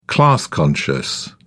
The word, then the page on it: class-conscious: British English